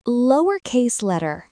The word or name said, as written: lowercase letter